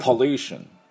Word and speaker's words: pollution: で、ルーのところにアクセントがあります。ですからこれしっかりやっていきたいと思います。では私の後について発音いきましょう。 Pollution。